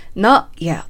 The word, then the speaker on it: Not yet: これをですね、 Not yet と小さい 2 を入れてしまわれる方結構いらっしゃるんですけれども、入れない方が英語らしいですね。 Not yet と止めるだけで発音すると英語らしくなります。